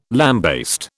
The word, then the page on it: lambaste: British English